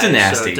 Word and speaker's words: Danasty: I think it's Dynasty, or I don't know. It's spelled D-A-N-A-S-T-Y. – It's Dynasty. – So Dynasty.